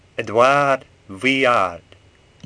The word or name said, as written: Vuillard